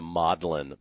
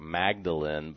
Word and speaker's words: Magdalen: Now, notice I'm pronouncing it the Magdalen Papyrus. It actually looks like Magdalene, but that's how Americans pronounce it. You have to pronounce it like you're living in Oxford, the Magdalen Papyrus that Magdalen College has.